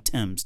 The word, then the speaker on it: Thames: I wanted to talk about this river where it says pronounced T-E-M-S. That sounds like Thames to me.